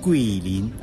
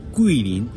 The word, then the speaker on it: Guilin: Esta ciudad se llama Quilín. Quilín, G-U-I-L-I-N. – ¿Sí? – Pero en la fonética china, G-U-I se pronuncia Qui. Entonces, el nombre de esta ciudad es Quilín.